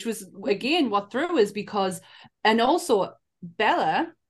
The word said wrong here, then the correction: Bela: who's in the movie as well, or Bela, sorry, I said I would pronounce his name right. It's Bela Lugosi.